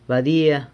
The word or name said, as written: badia